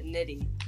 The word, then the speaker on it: Nitty: So I also wanted to ask you, how do you actually said your name? I didn't want to, you know, mess it up or anything like that. – Yeah, you pronounce it Nitty. That's how it is.